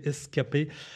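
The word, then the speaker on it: Ska-P: Scapay, donc prononcé Escapé.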